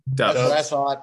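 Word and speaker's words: Doubs: Is it Dubs or Dobbs? Because I've heard a lot of Dubs lately. – Dubs.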